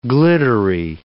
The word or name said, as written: glittery